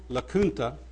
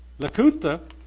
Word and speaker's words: Lacunza: While they were expelled from Chile, Emmanuel Lacunza, it's spelled L-A-C-U-N-Z-A, like Lacunza, but it's pronounced Lacunza,